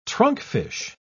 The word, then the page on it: trunkfish: British English